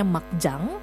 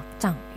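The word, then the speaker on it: 막장: Nah, menurut kalian, kalau tadi kita sudah belajar kata mak doang ya, kalau kata makcang, pada tahu kan ya makcang, atau mungkin taunya makjang ya, pokoknya tulisannya makjang tapi harusnya dibacanya makcang ya.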